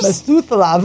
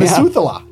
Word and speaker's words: Methuselah: Methuselah of trees. I didn't even say that right. – Yeah. Methuselah.